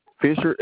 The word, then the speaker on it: Fisher: You said M-A-L-C-O-L-M? – Yes, sir. And the spelling of your last name? – Fisher, F-I-S-H-E-R. – Gotcha. This H-E-R.